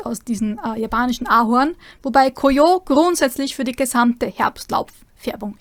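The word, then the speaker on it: Koyo (こうよう): Da gibt es zwei Aussprachen. Die japanische Lesung, das ist Momiji. Momiji. Oder die chinesische Lesung Koyo. Koyo.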